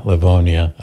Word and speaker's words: Livonia: And did you see what Barbara did here in, how do you say that, Livonia, Michigan? – Yeah, Livonia.